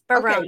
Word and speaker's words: Barone: Yes, Barone. – Okay, you're right. – Okay, got it right. Good. – Just like Raymond.